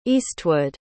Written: eastwood

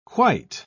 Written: quite

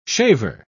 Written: shaver

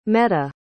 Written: metta

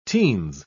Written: teens

